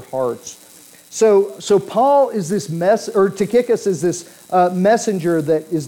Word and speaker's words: Tychicus: Tychicus, and I had to look up how to pronounce this name because I've heard it pronounced a variety of ways, but it is Tychicus.